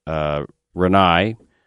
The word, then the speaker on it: Rinnai: We concentrate on Rinnai, which is spelled R-I-N-N-A-I.